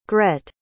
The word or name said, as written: grette